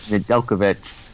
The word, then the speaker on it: Nedeljkovic: Nadel- Nedeljkovic from Carolina for Jonathan the rights to Jonathan Bernier in the third round pick in 2021.